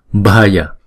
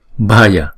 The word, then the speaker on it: bhaya: Chẳng hạn như chữ này, chữ B đứng một mình chúng ta sẽ đọc là b. Nhưng chữ B ghép với chữ H chúng ta đọc là b b, đúng không? Cho nên chữ này chúng ta đọc là b b. Chữ A này nè, chúng ta đọc a a, ghép lại là b bhaya bhaya sarva bhaya vigate.